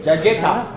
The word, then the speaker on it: cajeta: Cajeta diye bir yiyecek. – Evet. – Caceta. – Evet, öyle okunuyor.